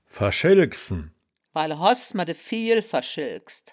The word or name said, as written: ver-schelksen